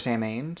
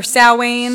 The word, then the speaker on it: Samhain: Or Samhain. – Samhain. – It's spelled Sam Hain, but it's technically supposed to be pronounced Samhain. Like, it's a Celtic word.